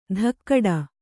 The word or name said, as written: ಧಕ್ಕಡ